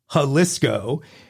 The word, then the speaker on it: Jalisco: The correct pronunciation is Jalisco.